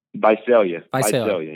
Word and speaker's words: Visalia: Visalia, yeah.